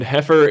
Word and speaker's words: heifer: Oh, and heifer is pronounced with a short E sound, and the I is silent. Rhymes with never, kinda.